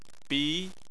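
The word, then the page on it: bi: tone 2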